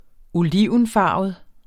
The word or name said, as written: olivenfarvet